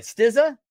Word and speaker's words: Stizza: Stizza? – Perfect.